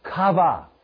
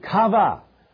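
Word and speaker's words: kava (קוה): Well, the second word for wait is the word kava. Q-A-V-A-H. That's how you say it. That's not how you spell it. But kava.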